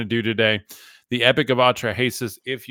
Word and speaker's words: Atrahasis: Atrahasis is how I pronounce it.